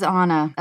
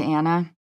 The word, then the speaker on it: Anna: Hasan, you never have to say my name, but it is Anna as opposed to Anna. My parents did that to me.